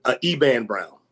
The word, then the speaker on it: Eban Brown: Eban Brown.